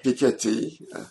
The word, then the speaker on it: Piketty: And you are a translator of many books, including one book that I'm holding up here, Capital, by Thomas Piketty, I think is the right way to say it. – Piketty.